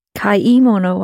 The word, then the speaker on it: kaimono: Kamono wa. – Kaimono wa. – Oh, it's the two syllables.